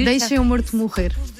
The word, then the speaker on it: Deixa o Morto Morrer: There is a track called Deixa o Morto Morrer. Am I pronouncing that? Am I butchering that pronunciation? – It's in Portuguese, it's very hard to pronounce Portuguese, but it's almost perfect.